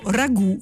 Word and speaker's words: ragù: Eh, la grafia ha oscillato a lungo tra la riproduzione della forma francese ragù, scritta ragout, e l'adattamento italiano ragù con la, l'accento sulla U finale.